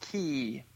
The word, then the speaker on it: Cay: I believe it's pronounced Key. – Yes. – Coco K. – Just like Cassaway Key. – It's spelled C-A-Y.